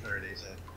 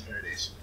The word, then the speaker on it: Farnese: You are absolutely right. It is Ferengis.